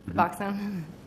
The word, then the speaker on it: Suboxone: What is Suboxone? Am I pronouncing that correctly? – Suboxone. – Suboxone.